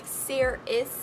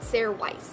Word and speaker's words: Sairys: Lastly, we have Ceres, and Ceres is the elemental dragon guardian of air, and it is spelled S-A-I-R-Y-S and is pronounced S-A-Y-R-I-S-S. So like Ceres, you know what I'm saying? Not Sarah Weiss, because that's kind of how it's like spelled.